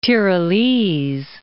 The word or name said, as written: Tirolese